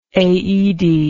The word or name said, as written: Aoede